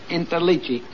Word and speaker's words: Interliggi: My name is Joseph Intellegi. I-N-T-E-R-L-I-G-G-I.